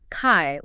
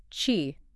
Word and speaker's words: qi: It's not kai like kite. – Okay. – It's more like chi with a soft shi.